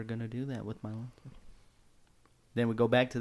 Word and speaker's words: Mailata: And then in the third round, they get him Patrick Mahomes a protector and Jordan Mailata from the tackle formerly of the Eagles. – Say Mailata. – There we go. Say it right. – Mailata.